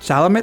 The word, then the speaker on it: Chalamet: Ahora, mejor actor principal están Timothy Chalamet, no sé cómo se pronuncia. – Chalamet.